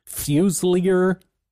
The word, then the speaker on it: Fuselier: It's spelled F-U-S-E-L-I-E-R. Fuselier is... – Fusel? – Fuselier is what I've heard, so that's what I'm going with.